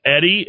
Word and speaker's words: Eddy: It's Eddie.